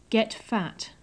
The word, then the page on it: get fat: English